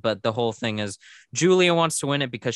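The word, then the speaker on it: Giulia: Oh, it's Julia. – Julia. – But it's spelled like G-I-U. Yeah, Julia.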